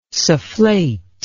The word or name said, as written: sufflate